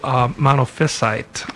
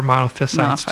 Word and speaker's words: monophysite: Some people, in fact, probably most people pronounce it monophysite. Okay. And we've had an argument at the seminary about how that's pronounced, and some have sided with me, saying that the accent is on the penultimate rather than, well, anyway. I'm just going to continue calling it monophysites.